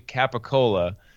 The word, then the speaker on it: gabagool: It's actually Capicola is the real way it's spelt and should be pronounced, but in Staten Island, Capicola became Gobble Goal. – Gabagool.